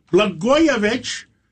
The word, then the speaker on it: Blagojevich: And we have former governor Rod Blagojevich.